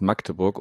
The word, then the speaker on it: Magdeburg: Ich muss mir jetzt gerade selber, habe ich jetzt festgestellt, ich habe es eben wieder falsch gesagt. Das heißt Magdeburg und nicht mhm.